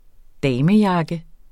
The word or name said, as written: damejakke